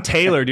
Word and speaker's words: Taylor: Like, I'm Taylor, dude, and I get Tyler all the time.